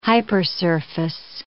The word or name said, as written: hypersurface